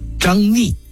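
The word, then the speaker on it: 张嶷: 这还不算，接着诸葛亮又说了：哎，把这张逆跟张毅也叫进来。张逆，这个逆字上边一个山水的山，下边一个怀疑的疑，此字有两个读音，一个呢是怀疑的疑，就念疑，另外一个读音在人的名字里头就念逆，张逆。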